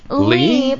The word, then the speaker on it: Leigh: Lee? But I wish it was Lay. – Well, the way it's spelled, L-E-I-G-H.